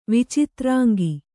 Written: ವಿಚಿತ್ರಾಂಗಿ

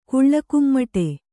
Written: ಕುಳ್ಳಕುಮ್ಮಟೆ

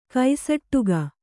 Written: ಕಸೊಡಿಗೆ

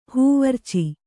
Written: ಹೂವರ್ಚಿ

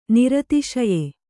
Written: ನಿರತಿಶಯ

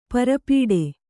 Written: ಪರ್ಪಟಿ

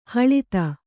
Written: ಹಳದಿ

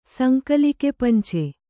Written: ಸಂಕಲಿಕೆಪಂಚೆ

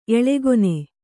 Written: ಎಳೆಗೊನೆ

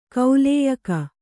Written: ಕಾಲೇಯಕ

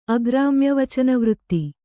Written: ಅಗ್ರಾಮ್ಯವಚನವೃತ್ತಿ

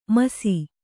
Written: ಮುಸಿ

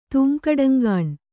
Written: ತೂಂಕಡಂಗಾಣ್

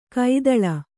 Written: ಕೈತಳ

